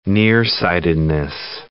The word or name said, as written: nearsightedness